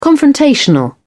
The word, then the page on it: confrontational: British English